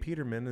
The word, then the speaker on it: Peterman: And Nate Peterman is still, I said his name right, didn't I? – Yeah, you did.